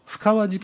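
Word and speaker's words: 布川: 深川事件と言います布の川と書いて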